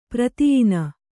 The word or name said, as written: ಪ್ರತಿಯಿನ